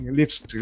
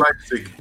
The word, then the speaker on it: Leipzig: Você pega um, um RB, o RB Leipzig, Leipzig eu nunca, nunca consigo pronunciar o Leipzig. Leipzig. E, Leipzig.